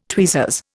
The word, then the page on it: tweezers: Indian